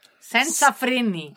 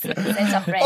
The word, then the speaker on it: Senza freni: 첫 번째 곡이, 제가 발음이 맞나요? 센제나 프레니. – Senza freni. – Senza freni. – Correct. Senza freni.